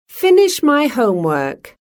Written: finish my homework

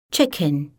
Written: chicken

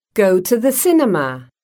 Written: go to the cinema

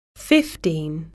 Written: fifteen

